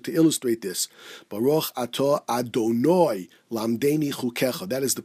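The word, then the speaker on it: Adonoi (אֲדֹנָי): Ashkenazim should certainly pronounce the way, as I said it, Baruch ato Adonoi lamdeni chukecha, and not I, as Svardim pronounce it.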